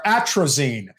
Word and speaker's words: atrazine: Atrazine.